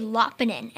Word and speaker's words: Lapinen: Actually, my grandpa, one day we went to visit his house, and my dad and my grandpa was like, and he said to my dad, he was like, it's actually Lapinen. And my dad was like, so I've been pronouncing my last name wrong for like 40 years of my life.